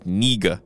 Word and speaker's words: Knigge: It's Nieg, right? – Nope. – What is it? – It's Niga. – No, it's not. – It is. – We're not saying that. – I'm just letting you know that's exactly how it's said. – I'm just saying it's spelled K-N-I-G-G-E.